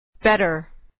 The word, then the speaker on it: better: Better. Better. Der. Better. Better. – در اینجا و در خیلی موارد که مخصوصا در تلفظ آمریکایی به صورت د نازک تلفظ میشه.